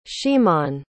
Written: ximon

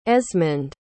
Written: esmond

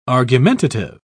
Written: argumentative

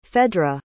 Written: phedra

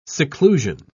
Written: seclusion

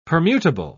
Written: permutable

